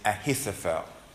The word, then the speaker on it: Ahithophel: His name is Ahithophel.